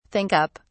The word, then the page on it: think up: British English